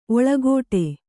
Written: ಒಳಗೋಟೆ

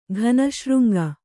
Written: ಘನಶೃಂಗ

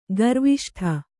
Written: ಗರ್ವಿಷ್ಠೆ

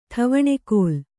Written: ಠವಣೆಕೋಲ್

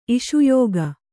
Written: ಇಷುಯೋಗ

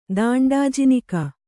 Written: ದಾಂಡಾಜಿನಿಕ

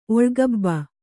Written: ಒಳ್ಗಬ್ಬ

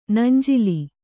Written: ನಂಜಿಲಿ

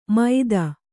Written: ಮೈದಾ

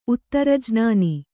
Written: ಉತ್ತರಜ್ನಾನಿ

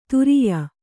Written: ಧೌರೇಯ

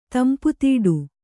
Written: ತಂಪುತೀಡು

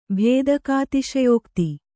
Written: ಭೇದಕಾತಿಶಯೋಕ್ತಿ

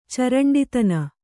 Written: ಚರಂಡಿತನ

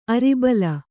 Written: ಅರಿಬಲ

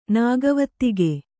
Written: ನಾಗವತ್ತಿಗೆ

